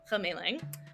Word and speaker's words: Hemeiling: My handle is Chemeiling. It's my Mandarin name. I will spell it for you. H-E-M-E-I-L-I-N-G, Chemeiling.